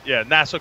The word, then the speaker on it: Nassau: Is it Nassau Community College? How do you pronounce? – Nassau Community College.